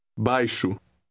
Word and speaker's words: baixo: The X in word 1 is pronounced sh. Listen and repeat it after the speaker. – Baixo.